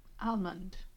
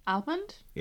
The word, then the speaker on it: almond: Almond. Almond? – Yeah. – Almond. – So some people say almond and hit the hard L.